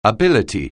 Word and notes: ability: anglais

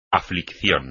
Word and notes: aflicción: espagnol

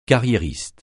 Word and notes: carriériste: francais

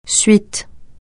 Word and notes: suite: francais

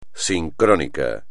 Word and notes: sincrónico: espagnol

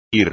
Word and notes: ir: Spanish

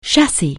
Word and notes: chassis: anglais

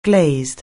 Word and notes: glazed: anglais

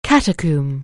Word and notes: catacomb: anglais

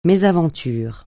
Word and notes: mésaventure: francais

